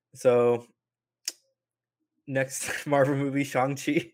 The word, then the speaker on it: Shang-Chi: It's always been Shang-Chi, and they have commented about how nice it was that people so quickly moved to the correct mm-hmm.